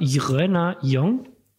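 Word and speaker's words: Jröner Jong: Und man schreibt das J R-Ö-N-E-R, neues Wort Jong, J-O-N-G. Also ich sage immer Irena Jong und finde, das entlarvt immer jeden, der nicht in Düsseldorf geboren ist, weil man kann es irgendwie als Nicht-Düsseldorfer nicht richtig aussprechen.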